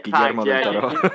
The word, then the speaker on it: Guillermo del Toro: Yeah, Guillermo del Toro.